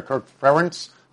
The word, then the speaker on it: Ferentz: It's very problematic for the longest tenured D1 coach out there, Kirk Ferentz. I hope I pronounce his name correctly. – You did.